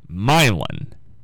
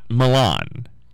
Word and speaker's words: Milan: But we had a gentleman call in right before I went on the air, said he grew up in that area, and it's pronounced Milan, not Milan. It's Milan. So,